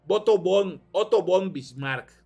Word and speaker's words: Otto von Bismarck: que dice que esta es de Otto van Bismarck. Espero haberlo pronunciado bien. Otto von Bismarck.